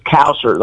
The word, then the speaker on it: Kowsert: Her last name is Kowser, like Kowsert. K-O-W. – Okay. – And then like Sert, like inserts, S-E-R-T.